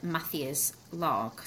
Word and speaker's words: Matthias Laug: My name is Sandra Patel and I'm delighted to be back here today recording with Matthias, Laug.